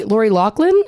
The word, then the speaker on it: Lori Loughlin: Lori Lori Loughlin is that how you say her name from Full House? – Mm-hmm.